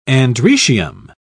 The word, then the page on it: androecium: British English